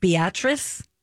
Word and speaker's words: Beatrice: It's spelled Beatrice, but it's pronounced Beatrice.